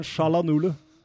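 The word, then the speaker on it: Çalhanoğlu: Hakan Shalanolo der, ja. Er det ikke det han heter? Jo, jo, jo.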